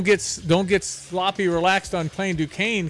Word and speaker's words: Duquesne: And how do you say who BYU is playing? – Duquesne. – Okay, it's spelled weird, but yeah, don't be surprised.